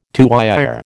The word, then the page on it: tuyère: American English